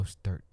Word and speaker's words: Mostert: I thought I messed up on his name, but I said Mostert. – It right. Mostert. – There you go. Emphasis on the T.